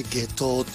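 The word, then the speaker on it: 夏油: 本日は下東ビレッジ株式会社共同代表の村岡陽子さんにお話を伺います。よろしくお願いします。 – よろしくお願いします。 – よろしくお願いします。 – 下東ビレッジ、ね、下東温泉、はい、夏の油と書いて下東って読むんですよね、はい、なかなかな読める方が少ないというね。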